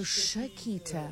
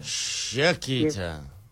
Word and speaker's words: Chiquita: So how do you say it? – It's Chiquita. – Oh, Chiquita. – Chiquita. Okay. Yes. Spelt with a C-H, though. – Yes, C-H-I-Q-U-I-T-A.